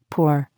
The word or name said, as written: pour